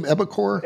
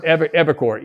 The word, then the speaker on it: Evicore: How do you spell that name, Evicor? – Evicor, E-V-I-C-O-R-E.